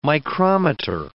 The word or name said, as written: micrometer